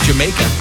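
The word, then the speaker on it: D'yer Mak'er: From 1973, Houses of the Holy, actually pronounced Jamaica, as spoken by the locals in that country.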